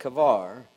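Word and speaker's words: Chebar: and this is actually pronounced Kavar, that the heavens were opened and I saw visions of God.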